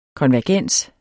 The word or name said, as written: konvergens